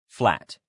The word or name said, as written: flat